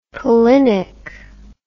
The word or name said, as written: pollinic